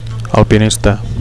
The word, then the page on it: alpinista: Catalan